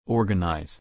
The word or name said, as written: organize